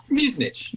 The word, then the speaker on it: Muzinich: Muzinich? – Muzinich.